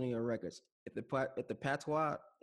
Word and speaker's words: patois: Is it patio? Is how you say it? – Patois.